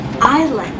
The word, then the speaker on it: island: La pronunciación correcta en este caso sería Island sin pronunciar la S.